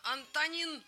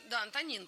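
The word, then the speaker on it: Antonín: Антонин, или Антонин, по-моему, да, он, у него ударение туда идет.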